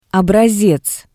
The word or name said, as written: obrasez